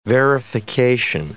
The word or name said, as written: verification